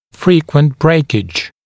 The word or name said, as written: frequent breakage